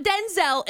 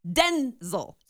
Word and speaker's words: Denzel: No, it is not Denzel. It's Denzel. So the accent on the den. – Almost on the N.